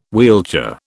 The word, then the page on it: wheelchair: British English